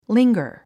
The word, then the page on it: linger: líŋɡər; リンガァ